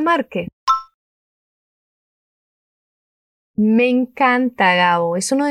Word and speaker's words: Márquez: La R suena un poco vibrante. Mar, Márquez. Ahora con esa entonación de pregunta. Gabriel García Márquez. ¡Qué estupendo! – Gabriel García Márquez, o Gabo, ya se ha muerto, era un escritor colombiano genial y ha sido galardonado ya con un Nobel de Literatura.